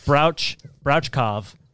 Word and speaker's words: Brouchkov: So this Russian scientist named Dr. Anatoly Brokstok. I don't know how to say his name. Brouchkov. – Yeah. – Brouchkov.